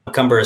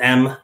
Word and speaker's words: McCumber: McCumber is spelled McCumber.